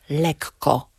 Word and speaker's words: lekko: Mówimy inny, a nie inny, ale mówimy lekko, zdecydowanie podwajając spółgłoskę, oddzielając te spółgłoski od siebie.